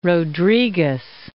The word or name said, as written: Rodrigues